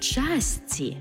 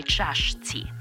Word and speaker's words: чашці: Мало чаю чи кави в часці, а не чашці.